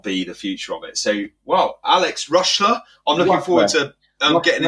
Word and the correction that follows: Rushmer: R-U-S-H-M-E-R, Alex Rushmer.